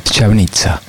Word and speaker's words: Štiavnica: Y Štiavnica es con la S con como con un paraguitas al revés, que se llama Mekchen, T-I-A, V corta o V, N-I-C-A. La C se pronuncia C acá. Chavnitsa.